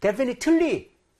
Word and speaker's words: definitely: 그래서 여러분 앞쪽에 강세를 줘서 발음을 해야 되기 때문에 이걸 할 때 definitely, 틀리 이렇게 소리가 나오면 발음 안 된 겁니다.